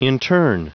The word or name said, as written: intern